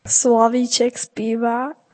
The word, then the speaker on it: Slavíček zpívá: It's not Slavíček zpívá, the nightingale is singing, but it's pronounced a little bit differently, isn't it? – Slavíček zpívá.